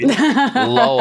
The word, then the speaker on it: Louisville: Louisville. – I was waiting. – Louisville. – I was waiting. – Louisville. – Louisville. – Louisville. So I'm sorry, buddy. You deserve better than that. Jen. – Louisville is kind of a Neapolitan city these days.